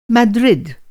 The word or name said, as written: मद्रिद